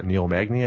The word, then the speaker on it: Magny: Yeah, and then fucking you had Damian Maya schooling Neil Magny. I think that's how you say his name. – Yep. Yeah.